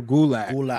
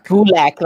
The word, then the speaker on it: Gulak: Like, we look at Drew, I want to say his name wrong, I hate when I do that, Gerlach? – Drew Gulak. – Gulak.